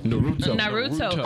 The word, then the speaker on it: Naruto: How do you say it? – Naruto.